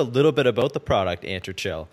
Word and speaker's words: Atrantil: Yeah, it's called Entrantil, like my belly's better. – Entrantil.